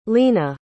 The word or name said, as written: lena